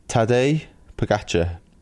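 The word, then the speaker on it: Tadej Pogačar: But Tadej Pogacar.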